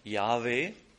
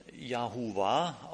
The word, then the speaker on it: Yahweh: Die genaue Aussprache weiß leider niemand. Es wird viel darüber geredet, es wird darüber nachgedacht, es gibt die verschiedenen Darstellungen und auch verschiedene Begründungen. Manche oder am geläufigsten ist uns vielleicht inzwischen die Aussprache Yahweh. Es gibt aber auch gute Gründe, davon auszugehen, es heißt, wird Yahuwah ausgesprochen. Wilfried